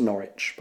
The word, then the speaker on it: Norwich: It's pronounced Norwich, by the way.